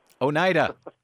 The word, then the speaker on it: Oneida: Brad, I was really gobsmacked when I heard you mispronounce the word Oneida. – Oneida. Thank you for letting me know.